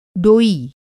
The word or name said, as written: डोई